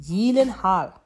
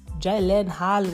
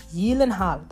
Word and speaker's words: Gyllenhaal: But he genuinely said it himself, that his surname is pronounced Gyllenhaal. So, I will keep doing that. And everyone else can keep saying the other one, Gyllenhaal, whatever. It's Gyllenhaal.